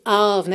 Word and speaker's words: of: Aqui também tivemos uma marcaçãozinha rítmica nesse of, né? Que é O-F, mas a pronúncia é A-V, né? Não é um O mesmo, é um A-A, aquele som mais bobo, né?